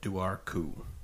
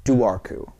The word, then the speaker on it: Dobharchu: because its name is the Duarku. – Duarku. – Duarku. – Okay. – Yeah. You know how it's spelled? – Nope. – D-O-B-H-A-R-C-H-U. – Nope. – Duarku.